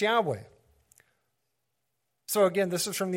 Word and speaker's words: Yahweh: And if anybody would understand how to pronounce the name of the Mighty One of the Israelites, it would be Jewish scholarship. And based on the evidence, based on the Greek evidence, they are saying and confirming that that name is Yahweh.